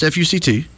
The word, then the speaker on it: FUCT: Supreme Court strikes down ban on scandalous trademarks in dispute over F-U-C-T clothing line. There was a clothing line. That's how it's pronounced, F-U-C-T. You don't shorten it and try to pronounce it all as one word.